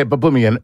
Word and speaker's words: Baboumian: Am I saying your name correctly, Baboumian? – Yeah, Baboumian's perfect. – Okay, Baboumian.